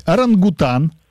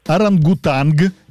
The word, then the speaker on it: орангутанг: Как правильно говорить? Орангутан, орангутанг или допустимы оба варианта? – Второй вариант.